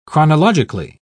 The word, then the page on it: chronologically: British English